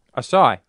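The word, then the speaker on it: açaí: How do you say the word A-C-A-I? – Asai.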